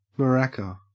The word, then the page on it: maraca: Australian English